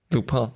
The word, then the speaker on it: Lupin: Lupin.